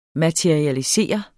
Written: materialisere

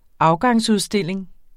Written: afgangsudstilling

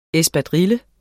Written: ESPADRILLES

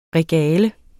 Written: regale

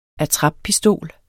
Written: attrappistol

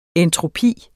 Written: entropi

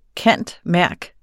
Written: cand.merc.

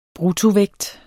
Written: bruttovægt

